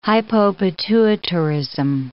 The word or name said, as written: hypopituitarism